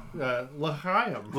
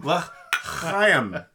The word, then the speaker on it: L'chaim: L'chaim. – L'chaim. That's pronounced, I think, with a ch.